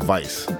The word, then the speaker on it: Weiss: Spelled with a W, but it's pronounced Weiss, like Miami Vice.